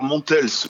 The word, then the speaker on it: Montels: Montels, on prononce le S à la fin ? – Alors Montels, oui, Montels. C'est très important de prononcer toutes les lettres.